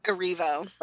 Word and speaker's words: Erivo: It's Erivo.